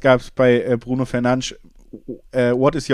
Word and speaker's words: Fernandes: Aber wichtigste Spieler natürlich äh, Bruno Fernandes oder Bruno Fernandes, das kann sich jeder selber aussuchen. Das wäre die korrekte Aussprache, habe ich gelernt.